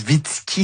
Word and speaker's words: Zwicky: ça s'écrit Z-W-I-C-K-Y, donc je sais pas si c'est Zwicky si on le prononce est-européennement, en tout cas, bon, c'était Fritz Zwicky, hein, c'est ça Julie? – Oui, exactement.